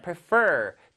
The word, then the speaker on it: prefer: 근데 여기는 prefer 뒤에 강세가 있습니다.